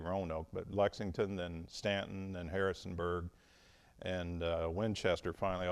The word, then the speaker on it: Staunton: But Stanton, and if you look on a map, it's spelled S-T-A-U-N-T-O-N, and most people will pronounce it Staunton. And if you're from Stanton, they will correct you very quickly.